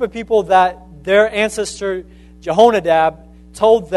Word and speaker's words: Jehonadab: Is that how you say it? Jehonadab? Jehonadab, yes, I said it right.